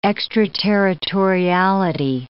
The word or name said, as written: extraterritoriality